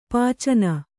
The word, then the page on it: ಪಿಚ್ಚನೆ: piccane